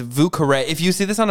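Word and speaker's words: Vieux Carré: Did you guys know that the cocktail is pronounced Vucare? Oh my God. I thought it was Vucar.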